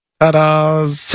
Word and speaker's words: Tadas: How do you think you pronounce the name T-A-D-A-S? – Tadas. – Tadas. – Tadas. – Tadas? – Tadas. – Yes. – Is that right? Tadas? – Tadas, yep.